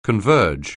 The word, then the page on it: converge: anglais